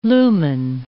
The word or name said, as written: lumen